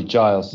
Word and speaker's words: Giles: It's a hard G, Giles.